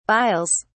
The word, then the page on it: biles: British English